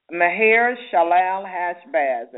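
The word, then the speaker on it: Maher-shalal-hash-baz: And the next child, the second child that they had was Maher Shalal Hashbaz, and if you could pronounce it better, do so. It's Maher-Shalal-Hashbaz.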